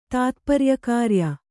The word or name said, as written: ತಾತ್ಪರ್ಯಕಾರ್ಯ